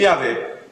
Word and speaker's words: Yahweh: Yahweh, bravissimo, Yahweh.